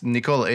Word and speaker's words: Nikola: And then they were like, oh, are we saying Nikola wrong? He's like, yeah, it's Nikola. It's the same Nikola way that I say my name.